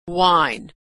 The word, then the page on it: wine: w